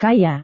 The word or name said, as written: kaya